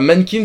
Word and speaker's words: MNNQNS: Mankins, un Mankins qui s'écrit, attention, c'est un peu chiant, qui s'écrit MNNQNS. Vous enlevez toutes les voyelles.